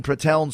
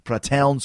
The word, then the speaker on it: Pratteln: 18, Pratteln, Switzerland. Cesaro had to teach me how to pronounce that. Pratteln, Switzerland.